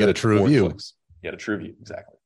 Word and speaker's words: TrueView: So, and on YouTube, you said those ads are called TrueView. Am I saying that right? – TrueView, exactly.